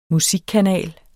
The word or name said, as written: musikkanal